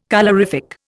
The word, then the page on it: colorific: Indian